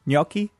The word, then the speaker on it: gnocchi: I said gnocchi. You said it's gnocchi. – Gnocchi. Did I say it right now? Gnocchi? – Yeah, you did.